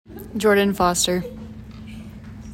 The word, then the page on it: Jordan Foster: JOR din FOSS ter